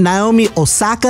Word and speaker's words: Naomi Osaka: Congratulations to tennis champion Naomi Osaka. Uh-huh. Did I say her name right? – Yep. Yes. Mm-hmm.